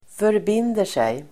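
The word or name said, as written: förbinder sig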